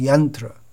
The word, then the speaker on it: yantra: but the standard pronunciation is yantra, and Kotriya.